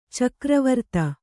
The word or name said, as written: ಚಕ್ರವರ್ತ